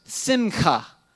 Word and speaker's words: simcha: And this word was simcha. It's spelled S-I-M-C-H-A.